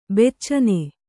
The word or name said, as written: ಬೆಚ್ಚನೆ